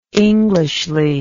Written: englishly

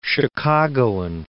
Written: Chicagoan

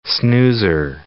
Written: snoozer

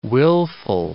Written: wilful

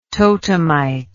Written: totemite

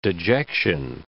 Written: dejection

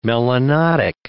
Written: melanotic

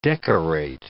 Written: decorate

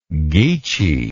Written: geechee

